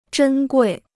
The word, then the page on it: 珍贵: zhēn guì